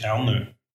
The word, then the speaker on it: Ernö: mein Name ist Ernö. E E-R-N-Ö vier Buchstaben und so, wie man ausspricht oder so, wie man schreibt, so spricht man es aus.